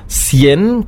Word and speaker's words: cien: Cien kennt jeder, 100. Das wäre auch ein Beispiel für CI. Cien, beide Male ausgesprochen wie ein S.